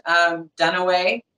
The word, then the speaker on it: Dunaway: Dunaway, just like Faye Dunaway. Holly Dunaway on any social media.